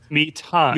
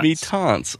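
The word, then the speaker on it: Mi-Teintes: How'd you pronounce that again? – Mitants. – Mitants, okay.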